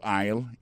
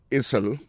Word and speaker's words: aisle: Go to aisle 11 and you will find it. Aisle là, c'est A-I-S-L-E. Vous prononcez l'aisle. Il marque Essel.